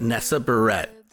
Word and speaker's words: Nessa Barrett: I hope I'm saying that right. Barrett, Nessa Barrett. – Close enough.